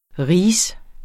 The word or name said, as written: rige